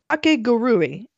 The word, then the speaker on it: Kakegurui: David, there's a Netflix series, original Netflix anime, called Kakegurui,